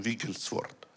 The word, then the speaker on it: Wigglesworth: Ich glaube, es war Wigglesworth. Habe ich richtig ausgesprochen? Wigglesworth, ja.